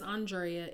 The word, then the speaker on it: Andrea: It's spelled A-N-D-R-E-A. It's spelled like Andrea. And people often mess up my name. And honestly, it doesn't bother me. But what does bother me is when I've been around people for a while and they continue to say my name wrong. And I would get offended because they would continue to say Andrea or Andrea.